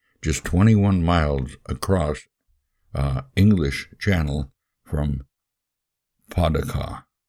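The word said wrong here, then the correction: Pas-de-Calais: and I'm not sure if I'm pronouncing that right. I think it's pas de uh, Calais, France.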